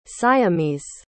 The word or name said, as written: siamese